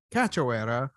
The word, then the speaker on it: Cachoeira: Cachoeira, or as I like to call her, Miss Nico Price.